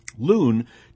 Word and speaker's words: Luhn: Loon takes her philosophical, by the way, that's spelled L-U-H-N, not L-O-O-N, although that does suggest itself. Loon, L-U-H-N, takes her philosophical cue not only from Kennan, but also from the science writer and physicist M.